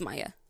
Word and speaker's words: Maja: Because I watched this with subtitles, and so it's spelled M-A-J-A. – Did you hear it pronounced, though? – It's Maya.